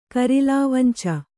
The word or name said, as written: ಕರಿಲಾವಂಚ